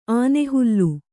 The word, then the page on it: ಆನೆಹಲ್ಲು: ānehallu